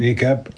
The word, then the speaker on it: niqab: Niqab. – N-I-Q-A-B.